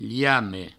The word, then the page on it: liame: provençal